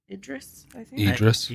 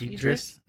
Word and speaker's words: Idris: Idris. – Idris.